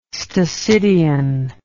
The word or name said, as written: stasidion